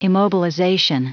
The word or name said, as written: immobilization